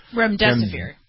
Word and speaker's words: remdesivir: Is it Remdesivir? – Remdesivir.